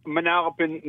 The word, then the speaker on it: Manalapan: What's the right way to pronounce where you live? – Manalapan, New Jersey. – Yeah, yeah. I knew I had the accent slightly off.